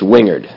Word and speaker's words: Wingard: It's Wingard. – Wingard. – Silent G. Wingard.